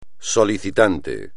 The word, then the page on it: solicitante: espagnol